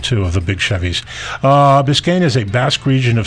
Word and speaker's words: Biscayne: Biscayne? – Biscayne. – I think it's B-I-S-C-A-Y-N-E.